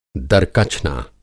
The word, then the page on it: दरकचना: Hindi